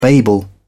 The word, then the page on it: babel: British English